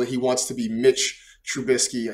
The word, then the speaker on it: Trubisky: But I think per Aditi Kinkabwala, he has said he prefers to be Mitch Trubisky. So let me adjust this here in our rundown so I say this properly. Mitch Trubisky, two-year deal, $14.25 million.